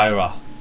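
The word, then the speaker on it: Hirai: And what's also interesting is the guy they've got replacing him is Kaz Hira, or I don't know how to pronounce it. Hira.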